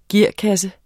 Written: gearkasse

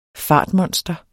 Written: fartmonster

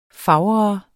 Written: fager

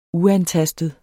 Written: uantastet

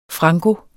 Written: franko

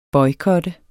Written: boykot

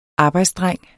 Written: arbejdsdreng

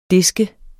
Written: disk

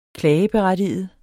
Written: klageberettiget

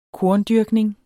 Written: korndyrkning

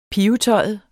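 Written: pivetøjet

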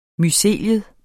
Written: mycelium

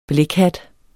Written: blækhat

